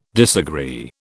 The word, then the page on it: disagree: British English